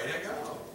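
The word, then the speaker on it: hagah: Haggah. – Right, Haggah. Haggah.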